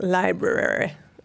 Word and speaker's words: library: It's more like library. She's sort of a few little extra. Her grandmother isn't actually English, but there's a little bit of a kind of formal. So it's a sort of library. You know, with library. – Rhyming with February, you know.